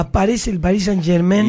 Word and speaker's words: Germain: Brutal. Paris Saint-Germain. – Exacto. Pronuncie bien, querido. – Germain.